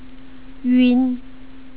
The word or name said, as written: yun